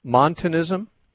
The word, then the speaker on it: Montanism: monasticism or monism. It was a kind of, these are two. – Montanism? – Pardon? – Montanism? – Yes.